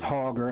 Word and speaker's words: Hauger: My first name is Joseph, and my last name is Hauger. That's spelled H-A-U-G-E-R.